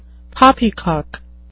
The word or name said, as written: poppycock